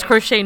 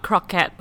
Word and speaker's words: crochet: Pronounce crochet, not croquette.